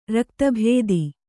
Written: ರಕ್ತಭೇದಿ

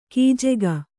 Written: ಖಜಾಕೆ

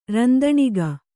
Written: ರಂದಣಿಗ

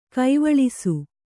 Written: ಗಾವಳಿಸು

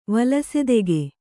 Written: ವಲಸೆದೆಗೆ

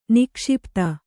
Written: ನಿಕ್ಷಿಪಿತ

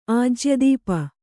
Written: ಆಜ್ಯದೀಪ

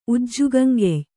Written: ಉಜ್ಜುಗಂಗೆಯ್

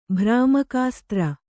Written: ಭ್ರಾಮಕಾಸ್ತ್ರ

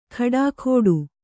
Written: ಖಡಾಖೋಡು

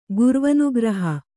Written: ಗುರ್ವನುಗ್ರಹ

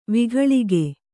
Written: ವಿಘಳಿಗೆ

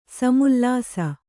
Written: ಸಮುಲ್ಲಾಸ

